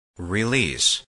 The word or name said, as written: release